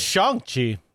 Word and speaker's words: Shang-Chi: Also, I found out it's Shang-Chi, not Shang.